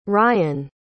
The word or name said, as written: ryen